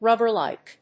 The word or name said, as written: RUBBER-LIKE